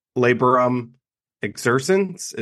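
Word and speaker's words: Laborem Exercens: And then Laborum Exercens.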